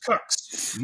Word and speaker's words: Qux: It's just okay, Q-U-X. Yes, it's pronounced Cux.